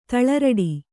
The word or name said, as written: ತಳಿರಡಿ